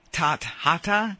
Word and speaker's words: Tathata: It's T-A-T-H-A-T-A. Tathata. It's pronounced Tathata.